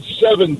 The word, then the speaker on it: Sevin: It's called Seven Dust, but it's spelled SEVIN.